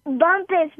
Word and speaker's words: Bumpus: Bumpus, B-U-M-P-U-S.